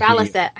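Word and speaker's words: baliset: This basilet. – Yeah, and Balisset. I always say that wrong.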